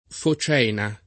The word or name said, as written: focena